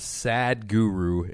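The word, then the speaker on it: Sadhguru: The guy's name is Sad Guru, and not because he's sad, it's just his name, but it's spelled S-A-D-H Guru, all one word.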